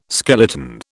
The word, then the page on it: skeleton: British English